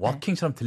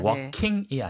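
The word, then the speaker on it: walk-in: 근데 그걸 walk-in이라고 해서 in을 해야 되는데 그 ing로 발음하시는 경우가 은근히 많더라고요. – 원래 스펠링이 walk하고 이제 dash in이잖아요. – 네네. – 그쵸? 근데 빨리 발음하면 walking처럼 들릴 수 있어요. – 그럴 수 있어요. – 근데 walking이 네. – 아니고 네. walk in.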